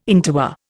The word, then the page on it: interwar: Indian